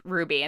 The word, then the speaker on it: RWBY: This is pronounced Ruby and not RWBY.